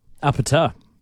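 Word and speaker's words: APT.: APT? How do you say that song? – Apata. You just absolutely nailed it.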